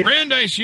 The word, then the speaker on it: Brandeis: That would be Brandeis University.